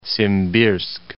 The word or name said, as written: Simbirsk